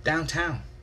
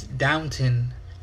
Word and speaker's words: Downton: Downtown. Downtown Abbey. It's downtown. And then I looked at it and I'm like, oh, there is no W. It actually is Downton Abbey.